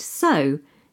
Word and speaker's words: sew: Something so simple, but that I always hear pronounced incorrectly, is the word S-E-W. Meaning to stitch something with a needle and thread. – It looks like it should be pronounced sew. – It does, but the simple fact of the matter is we pronounce it so, just like so.